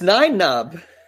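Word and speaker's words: Nien Nunb: Is it Nine-Nub? – Nine-Nub. – Something like that. – It's Nine-Nub.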